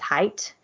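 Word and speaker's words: Haight: So the husband, Michael, and I don't know how to pronounce the last name, is H-A-I-G-H-T. I think it's Height. – I would say Height.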